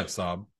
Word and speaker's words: Saab: Yeah, Saab.